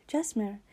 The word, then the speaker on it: Gesmhyr: So if you don't already know, my full name is Jesmer, which is spelt G-E-S-M-H-Y-R. Okay, I repeat guys, G-E-S-M-H-Y-R. I know that the spelling doesn't actually fit the pronunciation of my name, but yeah, that is it guys.